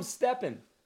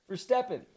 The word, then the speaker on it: Verstappen: You call him Steppen. Verstappen. His name is Verstappen.